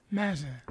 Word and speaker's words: Mazza: Well, you know, I'm Greek, but the M-A-Z-Z-A, it's a very common Italian name, and they would pronounce it Mazza.